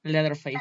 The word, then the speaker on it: Leatherface: Leatherface. – Ajá, Leatherface, este, de cuero.